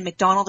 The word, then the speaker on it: McDonald: and McDonald is spelled M-C-D-O-N-A-L-D.